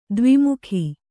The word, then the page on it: ದ್ವಿಮುಖ: dvi mukha